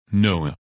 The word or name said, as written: Noah